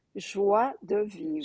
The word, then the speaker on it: joie de vivre: Joie de vivre, Joie, joy of life, joie de vivre.